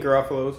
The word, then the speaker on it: Garofalo: Oh, Anthony Garofalo is fine. Garofalo is the one I heard a lot growing up.